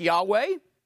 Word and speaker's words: Yahweh: The Hebrew word that you'll see there is actually a group of four letters, equivalent to, if we're turning them into English, YHWH, probably pronounced something like Yahweh.